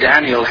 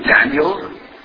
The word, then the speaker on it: Daniel: But you know that the Hebrew word Daniel has three syllables, Daniel. The English word at least two, Daniel. But Moody pronounced it with one, Daniel.